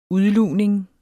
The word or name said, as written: udlugning